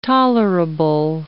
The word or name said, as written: tolerable